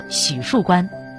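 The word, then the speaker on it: 浒墅关: 当时虎字和虎同音，第一个口误念成许树关的，相传是清乾隆皇帝。他在船头看到虎树关关旗时，虎字的三点水正好被遮住了，乾隆便说：“ 许树关到了，离苏州不远了。” 将错就错，这里就此念作许树关。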